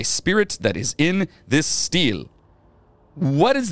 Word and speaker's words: stele: The Katamua Stele? – Steel. – Steel? – Yeah. – Why steel. Is it spelled like that? – Because it's fancy. – S-T-E-L-E? – Yeah. Stele. – steel.